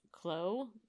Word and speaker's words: Claux: And his name is Nicolas Claux, C-L-A-U-X. It's French. So I'm going to assume that it is pronounced similar to faux.